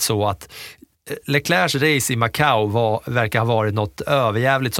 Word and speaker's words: Macau: Macau. Sättet som du gärna uttalar Baku, fast det är Macau.